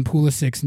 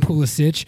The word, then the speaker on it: Pulisic: I mispronounced it the entire episode. I called him Pulisic. I always do that. It's because when he was first coming up, that's how I read it in my head, and I would always say it like that. And then I realized, you know, later on, it's Pulisic, but it's just out of habit.